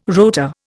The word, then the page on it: rotor: Indian